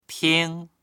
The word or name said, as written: ting1